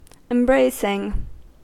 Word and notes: embracing: American English

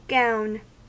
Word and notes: gown: American English

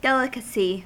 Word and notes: delicacy: American English

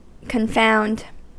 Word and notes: confound: American English

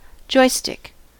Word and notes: joystick: American English

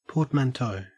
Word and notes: portmanteau: Australian English

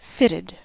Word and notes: fitted: American English

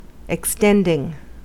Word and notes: extending: American English

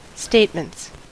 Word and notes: statements: American English

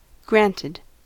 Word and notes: granted: American English

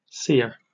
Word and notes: sere: British English

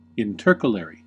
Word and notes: intercalary: American English

